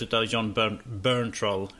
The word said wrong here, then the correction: Bernthal: Hur fan man uttalar hans Berntroll. – Burntroll. Bernthal.